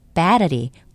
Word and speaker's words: battery: B-A-T-T-E-R-Y, battery, 건전지란 뜻인데요. 첫 음절에 강세가 있습니다. battery, 이렇게 말씀하셔야 됩니다.